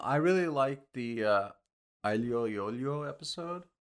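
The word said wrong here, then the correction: aglio e olio: Aglio Yolio.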